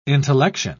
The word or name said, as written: intellection